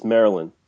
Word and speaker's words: Maryland: So, you mentioned you were in Maryland for, how do you say it, Maryland or Maryland? – Well, if you're a local, it's Maryland. – Maryland. Is there a D at the end? – Yep.